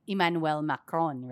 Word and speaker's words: Emmanuel Macron: So it's Emmanuel Macron, right? – Right.